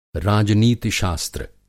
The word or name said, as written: राजनीतिशास्त्र